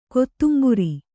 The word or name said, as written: ಕೊತ್ತುಂಬುರಿ